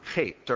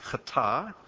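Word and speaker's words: chet (חטא): The first word, transgression, is the word chet, or in most Hebrew Bibles pronounced chata.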